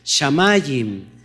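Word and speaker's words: Shamayim (שמים): Ahora, los cielos en hebreo, ¿cómo se, cómo se pronuncia? ¿Cómo se llaman los cielos en hebreo? Shamayim.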